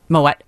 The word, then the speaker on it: Moët: Moët and Chandon. – Moët and Chandon. – Yes, you definitely pronounce the T for Moët.